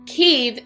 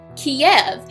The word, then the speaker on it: Kyiv: Oh, And before we go, Kyiv is the Ukrainian pronunciation. Kiev is the Russian one. It's amazing how one little syllable can make all the difference.